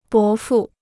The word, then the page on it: 伯父: bó fù